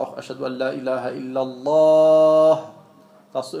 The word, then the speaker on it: illallah: Das war illallah, illallah. Ja, das ist hell, das ist nicht dunkel. Ja. Das, wie ich gesagt habe, das L bei Allah ist ein dunkel ausgesprochenes L.